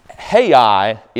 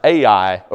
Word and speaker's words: Ai: Hai is actually pronounced Ai over in the book of Joshua.